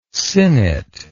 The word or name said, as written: sinnet